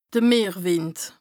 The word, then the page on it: de Meerwìnd: Bas-Rhin; Strasbourg